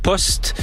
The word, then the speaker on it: Pust: C'est un groupe, je ne sais même pas comment le prononcer, c'est Pust Post P-U-S-T. – Post, je dirais post.